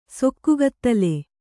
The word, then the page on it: ಸೊಕ್ಕುಗತ್ತಲೆ: Kannada